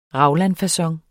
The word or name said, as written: raglanfacon